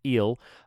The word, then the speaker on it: Ils: Again, I don't remember the year, but if you look up the French title, which again is spelled ILS, and thank you, Vera, pronounced Eel, you will find it.